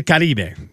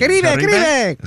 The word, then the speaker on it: Caribe: It's the Caribe. That's what it is. So it's Caribbean. – Caribe. – Is that how you say it? – Yeah. People in the Caribbean call it the Caribe, el Caribe.